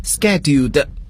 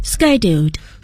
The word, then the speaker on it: schedule: 计划将要 scheduled. – Scheduled. – 英国英语我们要读作这个 schedule, 是吧？但是在美国英语中呢，我们要读作 schedule。